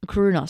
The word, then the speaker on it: Crooner: And we've had a lovely show going through this interesting era of jazz, the Coroner. Oh, I believe I pronounced that wrong, but this Crooner, sorry.